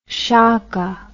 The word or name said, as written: shaka